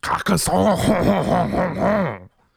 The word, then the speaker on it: Carcassonne: So that one is pronounced Carcassonne.